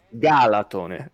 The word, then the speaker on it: Galatone: se quello è Galatina, l'altro sarà Galatone. – E invece, invece è Galatone.